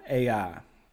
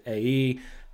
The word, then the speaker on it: Ai: So in chapter 7, they were defeated by Ai. And that's the proper pronunciation in case you don't know. I actually don't know. Some people pronounce it A-E.